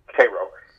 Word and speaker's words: Cairo: which is spelled like Cairo, but it's Cairo.